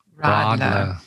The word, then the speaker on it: Radler: Um, so, Bob, if you listened, if you were attentive enough and listened like we did, then you found out that uh, Radler, no, no, I said that wrong. How do you say it? – Rodler. – Rodler. See, it looks like Radler because I'm from the South.